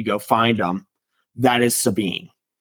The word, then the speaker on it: Sabine: Yeah, Sabine.